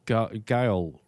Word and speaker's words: Gaol: That's at Roslyn Park, which is 30 Gale Road, I think I pronounce it. That's 30 G-A-O-L Road, Bendigo.